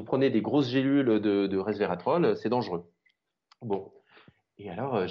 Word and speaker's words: resvératrol: Je prononce toujours mal en plus, c'est ridicule, mais qui est un antioxydant, le resvératrol. Oui, maintenant je le prononce bien.